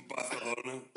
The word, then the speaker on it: Barcelona: Barcelona, the correct Catalonian.